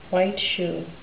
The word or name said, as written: white-shoe